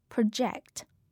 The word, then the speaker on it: project: 在这里提醒大家注意的是，作为动词时，它的发音是 project，和名词 project 的发音的重音是不一样的。